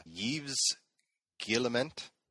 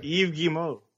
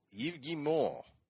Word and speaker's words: Yves Guillemot: So out comes, and I'm going to need your help to pronounce this, Yves Guillemot. – Not even close. – Okay, what is it? – Yves Guillemot. – Yves Guillemot. Yeah.